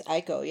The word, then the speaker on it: Aiko: And like Jenna Aiko, is that how you say her name? Jenna Aiko. – I think it's Aiko, yeah.